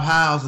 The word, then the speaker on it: Howze: Rob Howze, man. Rob Howze. And if you want to know how to pronounce the last name, it's like, how's it going? How's life?